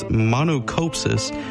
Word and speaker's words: monachopsis: And the next one is, this is kind of a hard one to pronounce, but it's monocopsis. It's spelled M-O-N-A-C-H-O-P-S-I-S.